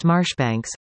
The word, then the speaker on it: Marjoribanks: Lady Aberdeen, born Ishbel Marie Marjoribanks, pronounced Marshbanks, was a person of outstanding ability with a strong interest in social reform, an interest shared by her husband.